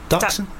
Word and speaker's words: Dachshund: by a poor Dachshund's life. Is it Dachshund? – Dachshund, that's it.